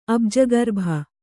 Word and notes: ಅಬ್ಜಗರ್ಭ: Kannada

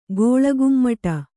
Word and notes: ಕುಳ್ಳಕುಮ್ಮಟೆ: kuḷḷakummaṭe

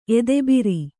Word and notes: ಎದೆಭಾರ: edebhāra

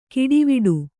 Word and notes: ಗಾಡಿವಡೆ: gāḍivaḍe

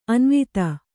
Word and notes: ಅನುವಾದ: Kannada

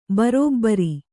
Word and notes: ಭರಭರ: kannada

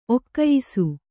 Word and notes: ಒಗೆಯಿಸು: ogeyisu

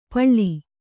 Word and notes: ಪಳ್ಳಿ: kannada

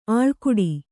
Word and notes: ಅಳ್ಕಾಡು: kannada

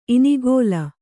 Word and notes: ಇನಿಗಲೆ: inigale

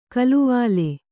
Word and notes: ಕಾಲುವಲೆ: kāluvale

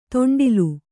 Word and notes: ತೊಂಡಿಲು: to'ṇḍilu

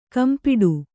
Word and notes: ಕೆಂಪಿಡಿ: kempiḍi

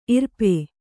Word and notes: ಇರುಪು: irupu

